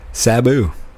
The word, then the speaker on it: Sabu: Sabu.